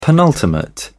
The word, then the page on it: penultimate: British English